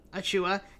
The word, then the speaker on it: Achiuwa: Achiuwa.